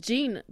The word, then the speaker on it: Jean: Et, côté anglais, va expliquer que la grand-mère, elle s'appelle Jean. Alors ça se prononce Jane pour les femmes, mais si mère quoi.